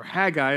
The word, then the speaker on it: Haggai: When you go to the book of Haggai, or Haggai, as some pronounce it, it says, in the second year of King Darius, on the first day of the sixth month.